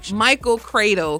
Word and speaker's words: Michael Cradle: So when we come back, we have to talk about McCreadle. Is that how you pronounce it? How do you pronounce the fashion line? – Michael Cradle Collection. This is nice MCC. Michael Cradle Collection, people.